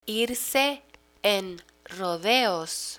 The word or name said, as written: irse en rodeos